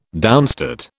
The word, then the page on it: downstate: British English